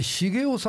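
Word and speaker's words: 成男: 日本歯科大学大学院の教授太田、成功の成に男と書いて、これで茂雄さんと読む、まあ確かにこのなるという字は茂と読みますが、これがお書きになって、これがって言ったら失礼ですが、これ続編なんですよね。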